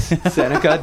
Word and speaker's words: Seneca: I said it rhymes with Renica. – It does. Seneca does rhyme with Renica.